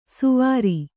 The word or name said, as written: ಸುವಾರಿ